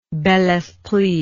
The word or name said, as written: belesprit